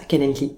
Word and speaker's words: Calendly: sur le site Calendly, je le prononce bien. Ouais, c'est ça, Calendly, exactement. – Comment, comment tu l'écris Alors, C-A-L-E-N-D-L-Y.